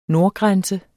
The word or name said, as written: nordgrænse